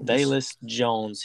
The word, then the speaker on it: Velus: Next guy, Velus. I'm saying it right, Velus or Bayless? – Velus Jones.